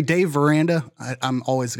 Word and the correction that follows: Aranda: Dave Aranda is much safer than Matt Campbell and Gundy, I would say.